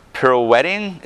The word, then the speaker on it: pirouetting: And what's the word? Pirouetting? Is that how you pronounce it? – Yes. Good job.